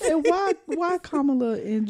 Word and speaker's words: Kamala: Kamala. – Say it right. – Kamala and Jill.